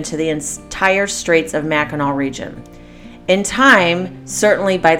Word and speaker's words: Mackinac: I found out through this Facebook page that I'm part of, Addicted to Mackinac, that all residents really don't like it if you spell it with a W. They want it with a C because that is how it is pronounced.